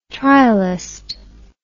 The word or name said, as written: trialist